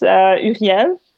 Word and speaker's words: Uriel: I think it's Uriel. – Real? – Yeah, I don't even know it. Like, it's because I pronounce it in French. In French, it's Uriel.